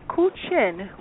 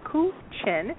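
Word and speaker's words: guqin: she also is a professional player of the Ku Chin, which is pronounced just like I said it, Ku Chin, and that is a Chinese zither, the most revered of all Chinese instruments.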